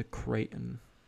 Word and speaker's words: Creighton: Is that pronounced Creighton? And U.S.? – Yeah, Creighton.